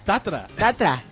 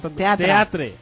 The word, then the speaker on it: Teatre: Tatra. – No se dice Tatra en catalán. – Se dice Tantra. – Teatre.